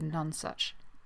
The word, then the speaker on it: Nonesuch: By the way, Rach, I don't know why, but I think maybe the first time I read it, I just had it in my head as Nonesuch rather than Nonesuch. I don't know why. It's definitely none, but I will probably say it wrong throughout this, and I apologise in advance.